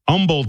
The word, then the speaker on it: Humble: I got dates in Grapevine, Texas, Humble, Texas, H is silent, Texarkana, Texas, Augusta, Kansas, Concordia, Kansas, Omaha, Nebraska, Cedar Rapids, Iowa.